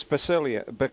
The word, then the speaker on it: Basaglia: It's Basaglia. Larry Basaglia. He pronounced it with a G.